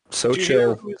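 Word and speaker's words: Xochitl: I just looked up the pronunciation. Sochil. Cool. – Thank you. So, Sochil.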